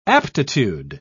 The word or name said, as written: aptitude